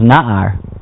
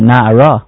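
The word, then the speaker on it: Na'ara (נער): Like, for example, it might say the word Nun Ayin Resh, which spells Na'ar, but you're supposed to pronounce it Na'ara, as if there was a hay at the end.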